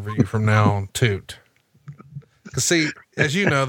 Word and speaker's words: Tuite: By the way, the Wall's real name, am I saying this right? Jerry Toot? – Yes. – T-U-I-T-E. Would you call that Toot? – Toot.